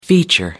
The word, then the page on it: feature: American English